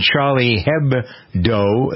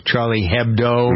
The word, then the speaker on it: Charlie Hebdo: Meanwhile, over in Paris, a shootout at the French satirical magazine Charlie Hebdo. That's how it's pronounced, I believe, Richie, right? Charlie Hebdo.